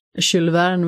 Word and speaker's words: Jules Verne: Jules Verne vill jag nog säga att det uttalas.